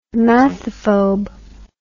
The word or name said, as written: mathophobe